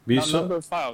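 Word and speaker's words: Numberphile: No, file. Number File. That's a Greek for love. It's how you pronounce it.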